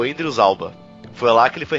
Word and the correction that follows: Idris Elba: O Idris Elba?